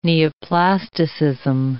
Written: neoplasticism

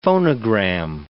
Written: phonogram

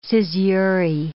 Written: caesurae

